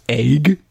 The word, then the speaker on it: AEG: It's pronounced egg. – What? – You have to say it like that. Egg.